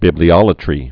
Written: bibliolatry

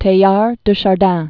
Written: Pierre Teilhard de Chardin

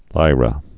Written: Lyra

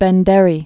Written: Bendery

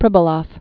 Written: Pribilof Islands

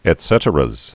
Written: etceteras

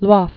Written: Lwoff

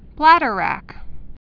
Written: bladder wrack

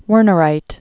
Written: wernerite

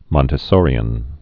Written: Montessorian

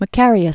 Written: Makarios III

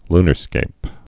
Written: lunarscape

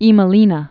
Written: Manuel Luis Quezon y Molina

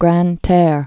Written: Grande-Terre

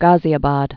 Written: Ghaziabad

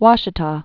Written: Ouachita Mountains

